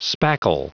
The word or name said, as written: spackle